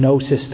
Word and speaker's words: gnosis: Gnostic comes from gnosis, the Greek word with a G. You can hear that silent G.